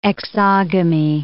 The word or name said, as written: exogamy